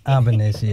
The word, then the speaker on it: Albanese: Albanese. – Albanese, yes.